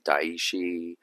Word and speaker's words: Daishi: I mean, living in Jersey, growing up in Cherry Hill, most of the people, they'll say Daishi, Dashi, Doishi, Dasani.